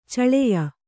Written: ಛಳೆಯ

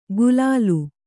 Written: ಗುಲಾಲು

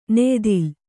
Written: ನೆಯಿದಲೆ

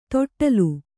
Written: ತೊಟ್ಟಿಲ್

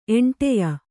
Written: ಎಂಟೆಯ

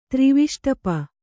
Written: ತ್ರಿವಿಷ್ಟಪ

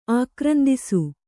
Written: ಆಕ್ರಂದಿಸು

